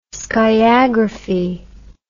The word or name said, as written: sciagraphy